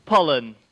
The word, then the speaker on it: Pollen: Pollen, like bee pollen.